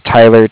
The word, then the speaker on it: Tyler: And again, my name's Tyler, T-Y-L-E-R, and I'm calling with Cardiff. It's spelled C-A-R-D-I-F-F, as in Frank.